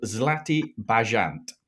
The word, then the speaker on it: Zlatý Bažant: It's called Zlatý Bažant, which means golden pheasant.